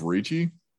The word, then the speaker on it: Ricci: I think it's Ricci, like Christina Ricci.